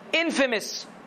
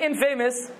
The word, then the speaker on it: infamous: It's I-N-F-A-M-O-U-S. How is it pronounced? Infamous. Famous, easy. When you add the in, you don't say infamous. You say infamous.